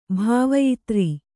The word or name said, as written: ಭಾವಯಿತ್ರೀ